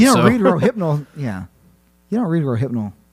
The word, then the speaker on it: Rohypnol: Cough syrup, LSD, rafiponal. Is that how you pronounce that? – No idea. – I screwed it up. – Rohypnol? – Yeah, there you go.